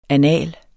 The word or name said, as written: annal